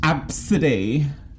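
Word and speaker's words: Abcde: It's spelt ABCD. – Oh. – And she like tried to sue an airline because an air hostess laughed at her kid's name. And she's like, it's pronounced Absidy.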